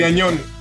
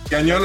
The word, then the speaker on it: Gagnon: No, like, Gagnon. Gagnon is perfect.